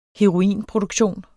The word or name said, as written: heroinproduktion